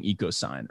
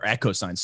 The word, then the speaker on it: EchoSign: I want to dive into, obviously, your experience building EchoSign, or EchoSign, sorry. I realized I said it wrong on Brendan's episode a million times, and now I finally got it.